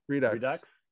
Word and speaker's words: Redux: How do you say that word? Redux or redo? Redux.